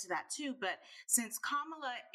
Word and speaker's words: Kamala: So it's Kamala, not Kamala.